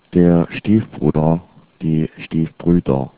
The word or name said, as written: Stiefbruder